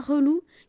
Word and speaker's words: relou: La prononciation du mot relou est très importante si l'on veut rester jeune. Il faut surtout accenter le re.